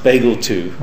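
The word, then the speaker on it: BglII: It's pronounced Bagel 2.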